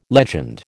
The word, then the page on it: legend: American English